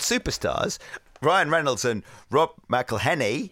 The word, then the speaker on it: McElhenney: Pronouncing all those N's and E's and H's can perplex him. So here's a little birthday gift from all your mates in Wrexham. It's McElhenney. What ways to massacre and mispronounce it, there are many. – McElhenney. – It's McElhenney. If there was doubt, now there won't be any.